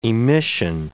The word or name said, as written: emission